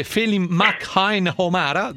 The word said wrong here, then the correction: Felim McNamara: giusto? – Felim McNamara. – Perfetto.